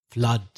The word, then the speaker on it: flood: People often pronounce it ooh, but it's a flood.